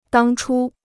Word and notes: 当初: dāng chū

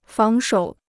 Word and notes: 防守: fáng shǒu

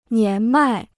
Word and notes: 年迈: nián mài

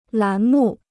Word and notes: 栏目: lán mù

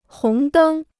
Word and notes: 红灯: hóng dēng

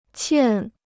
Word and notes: 欠: qiàn

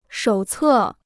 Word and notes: 手册: shǒu cè

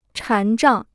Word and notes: 禅杖: chán zhàng

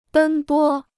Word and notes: 奔波: bēn bō

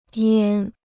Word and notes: 烟: yān

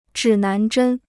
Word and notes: 指南针: zhǐ nán zhēn

